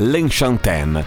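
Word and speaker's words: Lenchantin: Allora, teoricamente la pronuncia del nome di questa artista dovrebbe essere L'Enchantin, perché è un nome francese, anche se lei è argentina, infatti si chiama Paz di nome, Paz L'Enchantin, una grandissima, ottima musicista, anche una bellissima donna che ha 51 anni ma se li porta molto molto bene.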